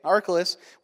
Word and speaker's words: Archelaus: But when he heard that Archelaus, am I saying that right? Okay, Archelaus was reigning in Judea in the place of his father Herod, he was afraid to go there.